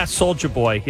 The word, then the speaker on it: Soulja Boy: Am I saying it right? – Yeah, Soulja Boy.